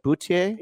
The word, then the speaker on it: Boutier: What was it again? Boutier? – Yes.